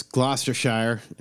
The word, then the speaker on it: Gloucestershire: It's Gloucestershire, and that's how it's pronounced.